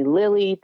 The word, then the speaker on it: Lilli: My mother named me Lily, but she spelled it L-I-L-L-I.